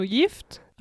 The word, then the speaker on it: Gift: Dann haben wir noch ein schönes Wortpaar, das man irgendwie auch äh, lustig interpretieren kann, denn es gibt ja das schwedische Wort Gift, G-I-F-T auf Schwedisch allerdings Gift ausgesprochen.